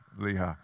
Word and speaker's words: Lehi: It says, when he came to Lehi, the Philistines, that's how, if you live in South Georgia, that's how you pronounce it, Lehi.